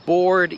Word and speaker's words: board: Ale też są takie słowa jak board i board. Board to jest deska. I board to jest znudzony. I wiem, że te słowa się pisze inaczej, ale je się wymawia dokładnie tak samo.